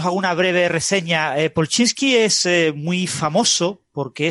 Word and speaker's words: Polchinski: Polchinski. – Polchinski, Polchinski, bueno, como sea.